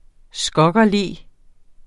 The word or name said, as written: SKOGGERLO